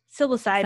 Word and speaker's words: psilocybin: Well, psilocybin. – Psilocybin.